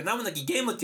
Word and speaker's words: 名も無き試合: はい、違うでございます。何もなき試合って書いて何もなきゲームって読むんでございます。